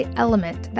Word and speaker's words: LMNT: This podcast is sponsored by Element. That's spelled L-M-N-T.